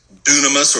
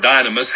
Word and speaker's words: dunamis: It's you've probably heard that the word in the Greek is dunamis or dynamis, however you choose to pronounce it.